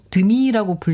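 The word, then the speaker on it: Demi: 아 그리고 이제 한 명씩 현재 호명하면서 어떤 경험이었는지 얘기를 하면서 데미무어 배우를 놓고는 아 데미는 드미라고 불리는 걸 더 좋아한다는 걸 알게 되었다고 그 얘기를 꼭 하고 넘어가시더라고요.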